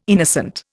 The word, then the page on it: innocent: Indian